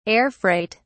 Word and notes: air-freight: British English

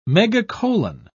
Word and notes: megacolon: British English

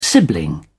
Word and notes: sibling: British English

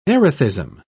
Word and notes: erethism: British English

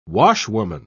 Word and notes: washwoman: British English

